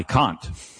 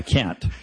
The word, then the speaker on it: Kant: No, it's not okay to say Kant. That's just wrong. It's done, but no, Kant is the correct pronunciation.